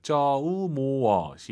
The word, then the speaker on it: 周末: 주말을 중국어로 쪼모가 아니라 오유는 어우예요. 쪼모어 시작. – 쪼모. – 쪼모어. 어로 끝나야 돼요. 모어예요.